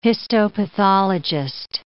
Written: histopathologist

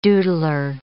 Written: doodler

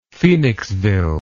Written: phoenixville

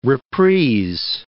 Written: reprise

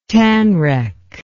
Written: tanrec

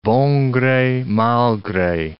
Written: bon gre, mal gre

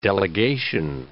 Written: delegation

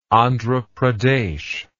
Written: Andhra Pradesh

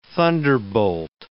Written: thunderbolt

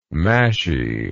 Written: mashie

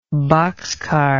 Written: Bockscar

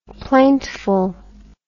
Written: plaintful

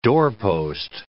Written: doorpost

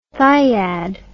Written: thyiad

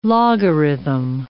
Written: logarithm

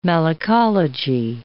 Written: malacology

